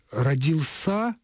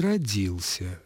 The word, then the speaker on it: родился: В прошедшем времени родился и родился, родилась и родилась, родилось и родилось, родились и родились. Как видим, в формах прошедшего времени этот глагол допускает ударение как на слоге, который следует непосредственно за корнем, так и на окончании, которое находится между суффиксом «ль» и возвратным постфиксом «си».